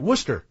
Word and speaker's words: Worcester: That's how they say it. Worcester. – All right. – Yeah. It's spelled Worcester, but everybody knows it's Worcester.